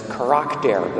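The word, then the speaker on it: charakter (χαρακτήρ): Character is a Greek word. They actually pronounce it charakter, but it's a Greek word.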